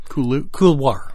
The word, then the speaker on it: couloir: Couloir? – Couloir. – Couloir? – Yeah. – C-O-U-L-O-I-R.